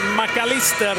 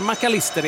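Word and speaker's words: Mac Allister: De säger McAllister i Argentina, de säger inte McAllister, även om man har ett engelskklingande namn.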